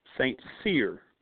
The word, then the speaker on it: Saint Cyr: Saint Cyr, like Sears and Roebuck.